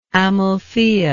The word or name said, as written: Amalthaea